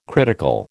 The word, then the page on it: critical: American English